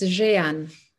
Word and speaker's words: Jehan: Am I pronouncing your name correctly? – It's hard to pronounce. It's Jehan.